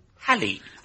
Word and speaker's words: Halley: you have compounded the solecism by mispronouncing his name, which should be spoken with a short A, as in Halley.